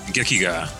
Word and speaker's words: gekiga: Gekiga.